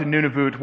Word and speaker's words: Nunavut: And I know some of you are thinking, isn't it none of it? Isn't it Nunavut? The answer is yes. I'm pronouncing it how they told me to pronounce it.